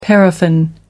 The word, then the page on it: paraffin: American English